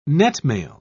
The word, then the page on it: netmail: British English